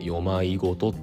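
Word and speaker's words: 夜迷言: よまいごと夜迷う迷いことって書いて「よまいごと」って読むんですけどもハチさんのバーチャル YouTuber のバーチャル YouTuber?VTuber?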